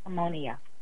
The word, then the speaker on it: Harmonia: Like harmonica. Harmonia.